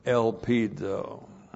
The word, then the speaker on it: elpizo: And then the verb elpizo. E-L-P-I-Z, long O, elpizo.